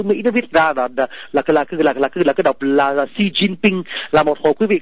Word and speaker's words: Xi Jinping: Chữ tập là đọc số hỏi tiếng Việt Nam Xí, còn chữ cận đọc dấu sắc tiếng Việt Nam chín, nhà mà chín nhớ là chín tiếng Nam á nha, chín yến thì yến tiếng Bắc mà là CH tiếng Nam, chờ yến chín. Còn chữ bình đọc là thỉnh, Xi Jinping, Xi Jinping.